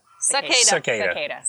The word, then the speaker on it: cicada: The cricket Acada? – Thing? Acada? – Acada. – Did I say that? I said that right. – Acada. – Yeah. – Cicada. – Cicadas, yes. Yeah.